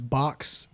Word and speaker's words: Baox: It's we were told it's box, but it's spelled B-A-O-X.